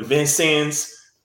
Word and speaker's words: Vincennes: And uh, one of the coaches asked about me and was like, hey, man, you ever think about coming to Vincennes? That's how you say it, university.